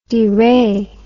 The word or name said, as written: deray